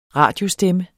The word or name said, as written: radiostemme